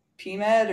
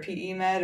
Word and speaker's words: PEMed: So, you know, you started Z-Tech, but then your longest running one, which I guess up until 2019, if I'm correct, is it pronounced PMed or PEMed or how do you pronounce it? – Sure, both ways, yeah.